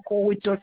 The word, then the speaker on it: Korutos: Je suis Madame Corutos, ça s'écrit avec K-O-R-U-T-O-S. – Oui. – Roselyne, ça s'écrit R-O-S-E-L-I-N-E.